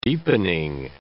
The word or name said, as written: deepening